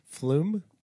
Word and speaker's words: Flom: We learned how to say their name. – Flume. – Flom. – Flom. – See, now I... – No, it's not. – Flume. Eli told us that we've been saying his name wrong for 5 years.